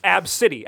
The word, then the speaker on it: Abcde: And the daughter's name was the letters A, B, C, D, E. – Yes. – You think so, but it was Agbade. – Absidy, actually, is how she pronounces it.